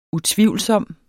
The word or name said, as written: UTVIVLSOMME